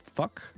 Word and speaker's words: PHUK: It is fuck, but then uh, pronounced PHUK, which stands for Please Help United Kingdom.